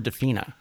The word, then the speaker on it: DeFina: We've got the producer, Barbara DeFina, if I'm pronouncing that right. – Yes.